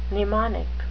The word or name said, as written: mnemonic